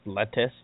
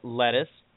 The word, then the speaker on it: Lettice: Is that actually pronounced lettuce? Yep, lettuce. It's spelled L-E-T-T-I-C-E.